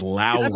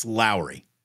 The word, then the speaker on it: Lauri: It is Lowry. – Are you serious? Like, that's how you really pronounce it? – Like the season salt, it's Lowry.